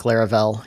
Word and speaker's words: Laravel: Laravel, is that how you say it? – I think Laravel. Yeah.